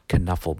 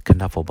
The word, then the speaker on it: Knuffle: and he has a book called Knuffle Bunny, um, or Nuffle Bunny. You could pronounce it with a silent K.